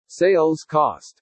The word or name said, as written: SALES COST